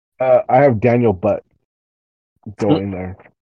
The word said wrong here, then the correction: But: Boot. – Daniel Boot. Is it spelled like butt? – It's B-U-T, but it's boot.